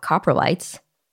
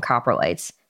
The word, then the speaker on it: coprolites: So you wouldn't expect organic materials to be preserved, but you can actually learn a lot from coprolites or coprolites. I've heard it pronounced both ways.